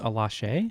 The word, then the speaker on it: Alashe: Alashi. I believe I'm saying that right. – I think it's Alashe.